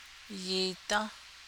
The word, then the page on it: yiidą́: Navajo